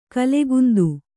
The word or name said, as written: ಗುಲಕಂದ